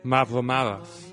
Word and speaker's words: Mavromaras: Mavromaras. – Mavromaras. Dude, you're Greek. You say it. – Mavromaras. – There you go. – You pronounce it just the same way I did.